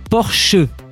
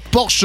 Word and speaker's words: Porsche: Ouais, mais on le prononce mal. Parce que normalement c'est Porsche. – Porsche. – Pas Porsche. Bah Porsche. Oui, allemand.